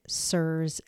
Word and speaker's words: CIRS: According to its founder, Dr. Richie Shoemaker, he pronounces it CIRS as in dear sir or madam. So let's go with that pronunciation. The spelling is C-I-R-S.